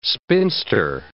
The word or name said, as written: spinster